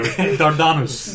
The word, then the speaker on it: Dardanus: I don't know if I'm ever going to say Let's agree to pronounce it in a much more Greek way. Dardanus.